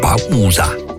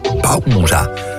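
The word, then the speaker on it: Bausa: spreek je dus gewoon op zijn Nederlands uit eigenlijk. Bauza. Bauza.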